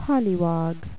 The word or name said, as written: polliwog